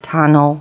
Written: tunnel

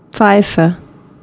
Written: apito

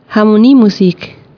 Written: kapela

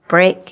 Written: brick

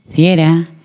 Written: ladybird

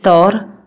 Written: lembu jantan

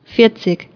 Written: amashumiamane

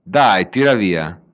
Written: streszczać się

